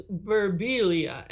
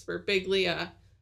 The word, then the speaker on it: Birbiglia: I've just watched his specials too much, but he's like, "My dad likes to pronounce our last name as Birbiglia." And I'm like, "Dad, stop it. It's Birbiglia. Order the pasta just like everyone else because stop pronouncing things like that, you know?"